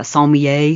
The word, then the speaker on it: sommelier: Think about like wine experts, like a sommelier. Is that how you say it? – Yes.